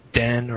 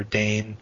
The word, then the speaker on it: Dehn: Uh, there is an H in there, uh, D E H N, silent H. Um, mm-hmm. But if if it is mispronounced as Den or Dane, that's right. okay too. There's people who pronounce it that way.